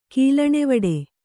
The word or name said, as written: ಕೀಲಣೆವಡೆ